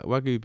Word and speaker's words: Wagyu: Wagyu beef. Am I saying that right? – Yeah.